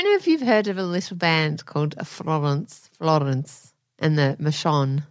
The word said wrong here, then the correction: Florence and the Machine: Florence and the Machine.